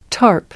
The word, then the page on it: tarp: British English